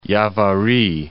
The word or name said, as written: Yavari